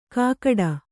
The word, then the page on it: ಕಾಕಡ: kākaḍa